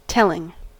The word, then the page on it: telling: American English